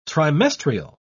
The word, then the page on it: trimestrial: British English